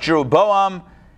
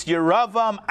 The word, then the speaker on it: Jeroboam: So I think in the English it's pronounced Jeroboam, Ahab, and Manasseh. However, it's Yeravam, Achav, and Manasseh in the Hebrew.